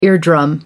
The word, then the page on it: eardrum: American English